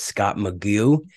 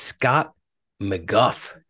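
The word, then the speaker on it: McGough: I thought it was Scott McGue, but it's Scott McGuff.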